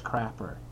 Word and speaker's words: Crapper: My last name is Crapper. C-R-A-P-P-E-R. It's spelled exactly like it sounds. And it sounds as horrible as it's spelled.